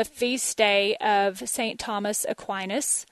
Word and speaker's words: Aquinas: And I won't mispronounce that like I got the month wrong at the beginning of the show. I tempted sometimes to say Aquinas because in Texas, that's how we would say it.